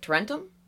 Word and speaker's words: Tarentum: Tarentum? – Yeah. – Okay. Okay. Right. – I know where Tarentum right.